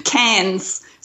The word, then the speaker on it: Cairns: I'm talking about like maybe Townsville or Cairns. I'm sorry, I did not say that right, but. – Cairns. So like Cairns in France, Cairns in France and Cairns in Australia.